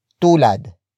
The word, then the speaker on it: tulad: Tularan is spelled as T-U-L-A-R-A-N. It is a command to emulate someone or something. It comes from the root word tulad. Tulad. Tulad, which is spelled as T-U-L-A-D.